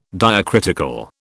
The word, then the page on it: diacritical: British English